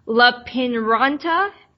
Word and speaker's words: Lappeenranta: She obtained her bachelor's and master's of science in economics and business administration from, I'm going to butcher this, Lappeenranta University of Technology in Finland, and her MBA from Aalto University.